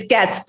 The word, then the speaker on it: Goetz: It's Goetz, but we get goats a lot.